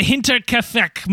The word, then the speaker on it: Hinterkaifeck: Welcome to Alien Theorist Theorizing, Case File 113, the Hinterkaifeck Murders.